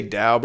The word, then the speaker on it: Tao: About half of them would go, is it Tao or is it Dao? And it's, well, I say Dao, but you know, these are Chinese words. All of us are mispronouncing. None of us are really getting it right.